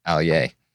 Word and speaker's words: Aller: It's written Aller, A-L-L-E-R, but it's actually pronounced Allier.